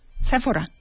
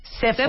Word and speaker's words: Sephora: Sephora. – Sephora. Sephora. Con acento en la E.